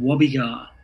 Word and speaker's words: Gwabegar: and it's called Gwabegar. It starts with a silent G, so it's a silent G W A B E G A R.